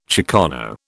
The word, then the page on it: chicano: British English